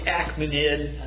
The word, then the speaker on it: Achaemenid: Persepolis is the Achaemenid, I think is the way you pronounce it, dynasty's palace.